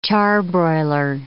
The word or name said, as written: charbroiler